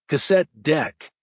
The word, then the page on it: cassette deck: American English